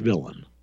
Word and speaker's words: villein: And it's spelled V-I-L-L-E-I-N, like villain, but it's pronounced English villain.